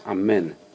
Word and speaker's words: amen: It's not amen, it's amen. Right? There's no a sound unless it's followed by yud. Anyway, so what is amen chatufa? Rashi says you pronounce the a very quickly. Amen. Amen. Very quick.